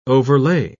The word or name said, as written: overlay